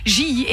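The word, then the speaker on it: Gilly: Oui, Jilly, et c'est comme ça que ça se prononce.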